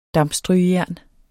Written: dampstrygejern

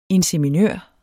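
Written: INSEMINØR

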